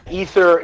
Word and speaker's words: Aether: Ether. Okay. – Yeah, Ether.